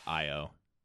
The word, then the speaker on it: Io: It's spelled Io. – Yeah. – Yeah. – And then it sounds like Io.